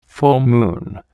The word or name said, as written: full moon